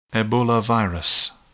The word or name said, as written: Ebola virus